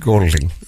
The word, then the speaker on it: Golding: And that's how he pronounces it, Golding? – Pete Golding. That's okay. Correct.